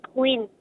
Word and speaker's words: Quinn: Queen. – Can you spell that for me? – Q-U-I-N-N.